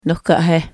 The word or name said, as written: nohkahehe